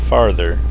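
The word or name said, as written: farther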